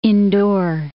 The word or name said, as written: Indore